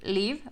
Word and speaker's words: Leve: Yo vi ahorita una TED Talk de una morra que se llama Ariel Liv, o sea, se escribe como Leve, Ariel Leve, con V,